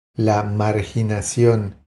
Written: La marginacion

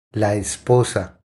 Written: La esposa

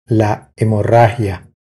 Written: La hemorragia

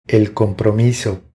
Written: El compromiso

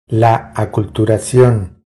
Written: La aculturacion

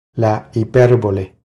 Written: La hiperbole

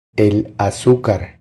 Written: El azucar

